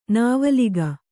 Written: ನಾವಲಿಗ

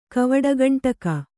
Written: ಕವಡುಗಂಟಕ

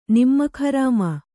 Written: ನಿಮಕ್‍ಹರಾಮ

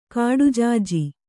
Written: ಕಡಜಾಜಿ

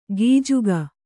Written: ಗಿಜುಗ

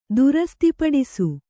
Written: ದುರಸ್ತಿಪಡಿಸು

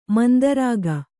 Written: ಮಂದಾರಿಕ

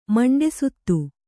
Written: ಮಂಡೆಸುತ್ತು

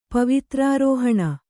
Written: ಪವಿತ್ರಾರೋಹಣ

